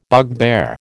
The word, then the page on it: bugbear: American English